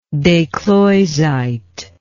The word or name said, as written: descloizite